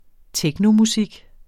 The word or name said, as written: technomusik